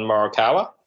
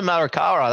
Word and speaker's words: Morikawa: I'm assuming you're referring to Colin Morikawa. – That's the one. Colin Morikawa, that's him.